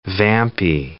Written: vampy

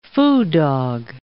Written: foo dog

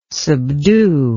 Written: subdue

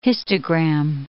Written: histogram

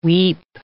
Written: weep

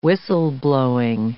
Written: whistle-blowing

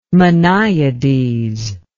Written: minyades

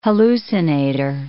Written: hallucinator

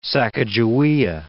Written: Sacajawea

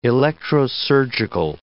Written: electrosurgical

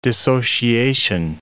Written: dissociation

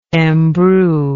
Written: embrue

